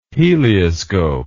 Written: helioscope

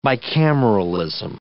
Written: bicameralism

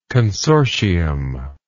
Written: consortial

